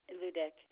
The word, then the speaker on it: Ludeke: Jessica Ludick. – You say your first name is Jessica? – I'm sorry, yes, Jessica. – What's your last name? Rubik? – Ludek, L-U-D-E-K-E. – Spell it again for me again. – L-U-D-E-K-E. – E-K-E.